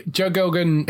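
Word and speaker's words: Joe Gilgun: Yeah, Joe Gilgan, who is, it's actually his show.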